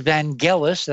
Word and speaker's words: Vangelis: NASA's Vangelis, that's how you're supposed to pronounce that, according to Kinthea, and she's an expert on art and music, and I will follow her lead.